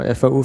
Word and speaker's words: FAU: Das ist nämlich hier das FabLab, das spricht man das an FAU oder V? – Ja, genau, FAU FabLab.